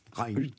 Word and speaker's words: Rijn: Ruin. – Rein. – Je ne sais pas le prononcer. – Rein. Ça s'écrit R-I-J-N. Et pareil, on me l'a mis. Hein. Prononcer Rein. Pour se faire chic.